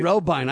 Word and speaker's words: Robyn: And my last name is actually pronounced Robine, like a turbine, but Robine. It looks like Robin because it's spelled R-O-B-Y-N, but the pronunciation is Robine, like a turbine.